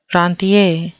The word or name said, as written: rentier